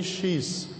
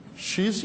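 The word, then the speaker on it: شیث: شیس، شیس یا شیس دونوں درست ہیں تلفظ، یا اردو میں ہم شیس تلفظ کرتے ہیں اور شاید عرب شیس تلفظ کرتے ہیں اس کو۔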